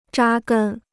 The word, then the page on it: 扎根: zhā gēn